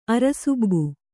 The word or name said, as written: ಅರಸುಬ್ಬು